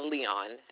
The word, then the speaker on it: Leon: Leon, L-E-O-N.